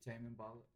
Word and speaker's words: Tame Impala: Yeah, like, different, like, Tamale Impala, you feel me? Like, Tame Impala? – Yeah, I can't even really pronounce it. That. – Yeah, bro, Tame Impala.